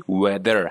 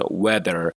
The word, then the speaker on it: weather: میبینید فرق بین W و W هستش که ما W رو و تلفظ میکنیم weather weather like window window